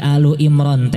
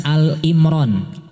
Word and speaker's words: Ali Imran: Jadi Ali Imran teh cara bacana sanes Al-Imran. Al-Imran salah.